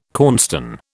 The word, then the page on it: cornstone: British English